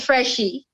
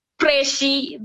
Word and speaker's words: Phreshy: As I was saying, my name is Freshie. P-H-R-E-S-H-Y. No Freshie. There's an urge, just like how you pronounce phone or faro.